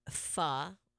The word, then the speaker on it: Pho: Pho, how it's properly pronounced, is rice noodles.